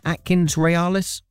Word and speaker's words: Atkins Realis: Is that right, Atkins Realis? – That's perfect.